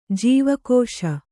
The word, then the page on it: ಜೀವಕೋಶ: jīva kōśa